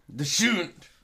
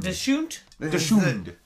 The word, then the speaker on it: Dachshund: It's spelled like the dog Dachshund. – Yes, it is. – And you pronounce it how? – Dachshund. – Dachshund? – Dachshund. – This is the family pronunciation.